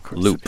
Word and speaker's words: Luup: this record, Secretly Hoping You Catch Me Looking, is out November 23rd, and the label it's on is Loop Records, but it's spelled L-U-U-P. Loop, pronounced Loop Records.